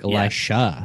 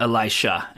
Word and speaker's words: Elisha: Oh no, it's one's an SH and one's a J. – Oh, like Elisha. – Yeah. Elisha and Elijah.